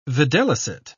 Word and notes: videlicet: British English

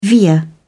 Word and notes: veer: British English

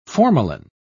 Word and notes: formalin: British English

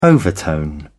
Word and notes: overtone: British English

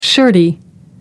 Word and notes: shirty: American English

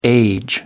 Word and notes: age: American English

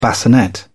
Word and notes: bassinet: British English

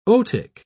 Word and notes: otic: British English

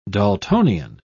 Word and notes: daltonian: British English

